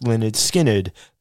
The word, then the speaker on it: Lynyrd Skynyrd: It comes from Lynyrd Skynyrd's 1973 debut album, pronounced Lynyrd Skynyrd, the same album that included Freebird.